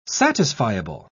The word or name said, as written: satisfiable